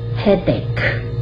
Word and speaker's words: headache: 那因为发音标的时候，你知道这个 c h e 它是发可。的音，所以是发 headache，那个字尾音又不发音了，甚至有人会把它只弄成两个音节。